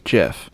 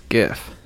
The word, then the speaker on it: GIF: A more recent one, just because I've ran into it a lot in my day-to-day right now, is people who pronounce GIF, JIF. I don't care if the creator of the GIF pronounces it JIF. He's wrong. It's GIF.